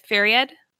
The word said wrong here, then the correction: Faraday: Is that how I pronounce his name? Fariad? The mentor. – Oh. – He's the one he comes into Citra's home and drinks lemonade. – Is it Faraday? – Yeah, Faraday. – Yeah, Faraday. Like Daniel Faraday. Michael Faraday.